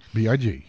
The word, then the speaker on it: B.I.G.: B.I.G.